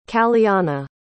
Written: caliana

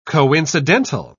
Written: coincidental

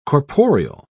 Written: corporeal

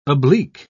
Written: oblique

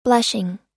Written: blushing

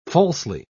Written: falsely

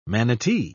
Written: manatee

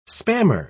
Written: spammer

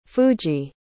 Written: fuji

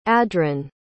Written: adron

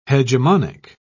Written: hegemonic

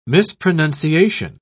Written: mispronunciation

